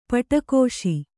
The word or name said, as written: ಪಿಡಿಕೈಸು